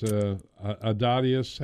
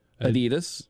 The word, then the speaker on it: Adidas: As I always do, you know, we start off, in his Adidas hat. – Adidas. Yeah, you kind of did the European, Euro-American pronunciation.